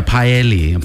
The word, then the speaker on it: paella: P-A-L. – Yeah. – P-A-E-L-L-A. – P-A-E, paella. It's yeah. Paella. That's definitely the way you say it, paella.